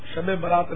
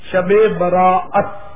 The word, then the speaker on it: شب براءت: شب برات دراصل وہ چونکہ زبان پر بیٹھا ہوا ہے, دراصل اس کا تلفظ شب برات نہیں ہے, شب براءت, براءت برات کے معنی چھٹکارا.